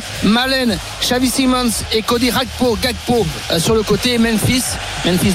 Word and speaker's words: Gakpo: Par exemple, non, je vais pas la jouer Rakpo, tu vois. Je vais jouer Gakpo.